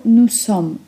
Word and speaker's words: nous sommes: Błąd polega na tym, że czasem wkrada się tam dźwięk z, który totalnie nie ma tam racji bytu. Wymawiamy to nous sommes z s, bo czasownik zaczyna się tam właśnie na literkę s, a literkę s na początku wyrazu czytamy po prostu s, czyli s.